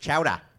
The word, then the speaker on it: chowder: Also, New England clam chowder is pronounced chowda.